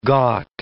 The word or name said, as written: ghat